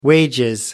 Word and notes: wages: British English

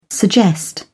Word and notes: suggest: British English

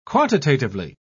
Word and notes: quantitatively: British English

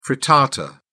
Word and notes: frittata: British English

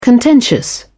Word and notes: contentious: British English

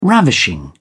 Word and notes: ravishing: British English

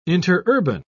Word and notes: interurban: British English

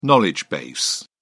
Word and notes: knowledge base: British English

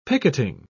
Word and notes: picketing: British English

